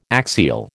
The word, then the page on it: axial: American English